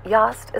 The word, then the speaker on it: Yost: Rhymes with Lost. Yost is the moniker of singer-songwriter and producer Kurt Sines.